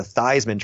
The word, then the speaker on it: Theismann: Joe Theismann, who changed his name from Theismann to Theismann to rhyme with Heisman.